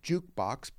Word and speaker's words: JKBX: We're called Jukebox, but spelled like a ticker symbol, yeah.